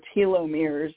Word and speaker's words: telomeres: Now, there's another patch which I love, and if any of our callers have or listeners have looked into telomeres, or telomeres is how some people pronounce it, T-E-L-O-M-E-R-E.